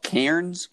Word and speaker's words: Cairns: I'll be here for another month, and then we're flying to, it's called Cairns. It's like spelt Cairns, but they don't pronounce the R.